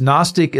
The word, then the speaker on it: Gnostic: Okay, so Gnostic, other than telling us it has that lovely silent G, what is Gnostic teaching?